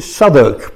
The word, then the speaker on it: Southwark: Yes, we pronounce it Southwark, but that's the English pronunciation.